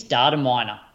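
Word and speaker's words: Dataminr: I think it's Data Miner. I think it's how you pronounce it. – Oops. Oh, Data Miner. Yeah. Okay. Yeah, that makes sense. Data Miner, but with no E.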